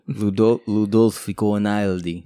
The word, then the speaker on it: Ludovico Einaudi: I don't know what his name is, but that I assume you butchered it. – Ludovico Einaudi. – Yeah. – There you go.